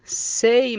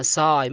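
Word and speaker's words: say: سي معناها يقول. شوف هنا نطقنا حرف الأي كما هي أي، ما نطقناها ساي، ما قلنا ساي، قلنا سي.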